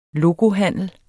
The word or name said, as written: locohandel